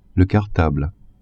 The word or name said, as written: cartable